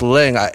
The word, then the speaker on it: Leung: Fuck, we can't pronounce Tony Lau. – No, it's Leung, L-E-U-N-G.